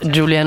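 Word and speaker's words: Giulia: Me gusta que me llamen Julia, porque mi nombre es Julia, no es Julia, porque se escribe con G de jirafa y italiano. – Yeah. – Y no Julia con J.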